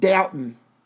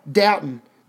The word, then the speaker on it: Dalton: Dalton, Ohio. D-A-L-T-O-N. Dalton. They all pronounce it Doughton. No L. Doughton. They live in Doughton, Ohio.